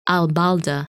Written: Albaldah

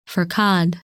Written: Pherkad

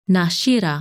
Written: Nashira

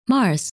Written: Mars

